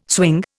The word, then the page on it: swing: Indian